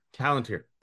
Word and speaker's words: Palantir: Say hello, is it to Palantir? – Palantir.